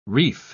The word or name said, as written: reif